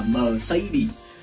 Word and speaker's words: Mercedes: Đọc là mẹp cũng không hẳn là sai bởi vì tiếng Đức người ta cũng đọc là mẹp nhưng trong tiếng Anh nó đọc khác hoàn toàn luôn, nó đọc là Mercedes.